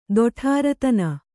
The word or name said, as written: ದೊಠಾರತನ